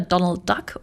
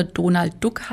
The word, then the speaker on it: Donald Duck: Also als ich die Stelle im Erika Fuchshaus 2021 begonnen habe, da hat mich Gerhard Severin darauf hingewiesen, vehement, dass es entweder Donald Duck oder Donald Duck heißen muss, also eben entweder komplett Englisch oder komplett Deutsch ausgesprochen.